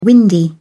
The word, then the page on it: windy: British English